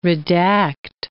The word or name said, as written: redact